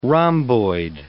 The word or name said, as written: rhomboid